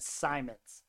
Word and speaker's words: Simons: And I almost said Anfernee Simmons, and that's not right. His name is Simons. Only one M on there.